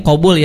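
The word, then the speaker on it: qabul (قبول): Antara qaf dan kan kalau ini qabul ya, kalau qabul itu artinya menerima, tapi kalau dibacanya qubul itu artinya jadi pantat tuh.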